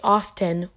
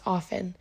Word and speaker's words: often: And then, like, even the word often is the word, but it has a T, so people say often, which is incorrect. – Wait, often is incorrect. But that's how it's spelled. – Correct. But it's incorrect to pronounce it that way. It's often.